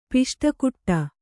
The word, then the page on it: ಪುಷ್ಟಿಕೊಡು: puṣṭi kōḍu